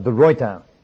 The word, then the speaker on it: de Ruyter: not de Ruyter as you folk pronounce it, but de Ruyter. The actual correct Dutch pronunciation is de Ruyter.